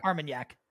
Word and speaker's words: Armagnac: Jake's gotten, how do I pronounce the seven? Armagnac? Like Cognac? – Yeah. Armagnac.